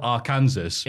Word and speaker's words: Arkansas: Which is spelled Arkansas, yeah. But it's a silent S.